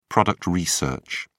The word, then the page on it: product research: British English